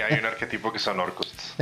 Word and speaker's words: Orcus: La primera viene siendo Orcus, es un príncipe demonio de los no muertos ¿Cómo se escribe eso? – O-R-C-U-S.